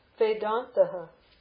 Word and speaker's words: Vedantaha: Vedantaha. That E is an A. You have to memorize that. That what is when A, B, C, D, E? E is pronounced A. So Vedantaha. For transliteration, when you see an E, it's an A in pronunciation. Long A, dental na nta, Vedantaha. Vedantaha.